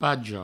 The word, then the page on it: paga: pago